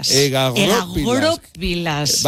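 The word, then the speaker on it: egagrópilas: Egagoropilas. – Vamos a ponerlo, o sea, el acento en la O.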